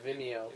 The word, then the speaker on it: Vimeo: Vimeo? – Yeah, Vimeo. – It's video with an M.